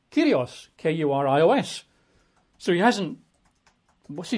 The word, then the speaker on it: kurios: We're reading an English translation, but the word Lord there in verse 12 is the Greek kurios, K-U-R-I-O-S, at least in English letters, K-U-R-I-O-S, pronounced, I think, properly, kurios, right?